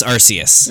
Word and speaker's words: Arceus: You know, the official pronunciation of Titus's name is Titus. They can be wrong. – Do you know what sounds fucking awful? Arceus. It's not Arceus. It's Arceus.